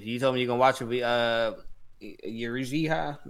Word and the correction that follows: Yuzuriha: I forgot how they pronounce it. – Yuzuriha. – Yeah, Yuzuriha. God damn it.